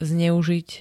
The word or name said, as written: zneuzit